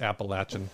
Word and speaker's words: Appalachian: It's Appalachian.